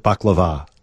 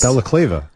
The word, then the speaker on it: balaclava: Or is it baklava? – I read it as baklava, but it isn't. – Balaclava.